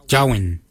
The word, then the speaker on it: Djowiny: Kart is spelled G-A-A-D. Kart. The next word is hair. For hair, we say jawin. Jawin. Jawin is spelled D-J-O-W-I-N-Y.